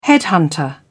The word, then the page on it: headhunter: British English